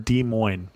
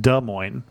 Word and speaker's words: Des Moines: I also heard from Marcia, who said, I love the Iowa podcast, but being born and raised in Des Moines, I must say it was like fingernails on the blackboard every time I heard Des Moines. It is pronounced Des Moines.